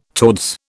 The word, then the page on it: towards: British English